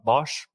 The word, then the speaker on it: Bosch: So he was poached from Bosch, where he, Bosch,